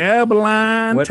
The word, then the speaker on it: Abilene: Abilene, Texas. – How do you pronounce L-E-N-E? Lean? – Line. With my draw.